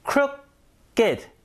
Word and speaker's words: crooked: 이리 붙여 가지고 형용사 만든 건데 crooked 이렇게 읽어야 돼요.